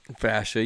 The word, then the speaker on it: fascia: Plantar fascia? Fascia? – Fascia, you had it right.